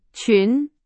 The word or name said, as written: 群